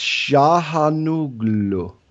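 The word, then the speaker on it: Çalhanoğlu: Turkish pronunciation. Hakan. Okay, so it's Şahanoğlu. Okay, whatever.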